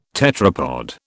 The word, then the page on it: tetrapod: British English